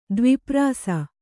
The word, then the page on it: ದ್ವಿಪ್ರಾಸ: dvi prāsa